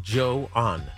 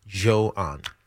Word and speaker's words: Johan: Johan. – Johan Duran. – So think of J-H-O is Joe and then A-N. Johan.